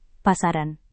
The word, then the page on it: pasaran: Indonesian